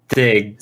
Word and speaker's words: Stijn: How do you pronounce S-T-I-J-N, guys? Stan? Sting? – Stig. S-T-I-J-N.